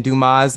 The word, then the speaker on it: Dumas: And Dumas is my middle name, and the S is silent.